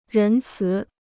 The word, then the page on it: 仁慈: rén cí